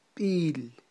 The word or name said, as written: b'il